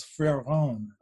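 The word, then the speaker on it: Fréron: His name was Freron, F-R-E-R-O-N.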